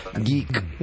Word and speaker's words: geek: Esta es la música, la cortina elegida para la columna de nuestro querido amigo productor, ciclista y tecnólogo, o geek, o como se quiera llamar. ¿Cómo se pronuncia usted que sabe tanto conjugar esas palabras? – ¿Qué cosa? – Geek. – Geek. No, lo hiciste bien.